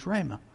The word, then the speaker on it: rhema: The word it uses here is rhema. Rhema. It is spelled R-H-E-M-A. Rhema. It's a Greek word.